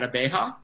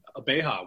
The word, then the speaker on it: Abeja: The winemaker at Abeja. Did I say that right? – Yes, you did. You nailed it. Abeja, which is Spanish for bee, honeybee.